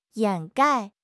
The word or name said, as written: 掩盖